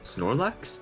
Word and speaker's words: Snorlax: Snorrock? – Snorlax? – Yo, okay.